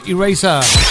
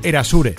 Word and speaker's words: Erasure: Eraser. Top 25. – Top 25. Top 25. – Esto es Kiss. Tardamos en aprender los locutores en pronunciar bien esto que durante mucho tiempo se presentaba como Erasure.